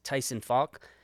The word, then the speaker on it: Tyson Falk: My guest today is Tyson Falk, government public policy manager at Ioneer.